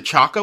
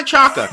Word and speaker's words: Chaka: Mr. Ainsworth, our shared gold star celebrates the first African-American woman to get a job as an NFL ref, Maya Shaka. This is awesome. She has a great name. Actually, I think she pronounces it Chaka, which is like a mispronunciation. But no judgment. Maya Chaka.